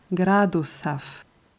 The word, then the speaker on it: градусов: Und bei der Aussprache von Gradusaf achtet bitte darauf, das S stimmlos auszusprechen. Saf, Saf, Gradusaf.